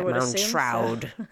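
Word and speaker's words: Moundshroud: and they meet Mr. Moundstroud. Is that how you pronounce it? – Mound Shroud. So. – Shroud. Mound Shroud.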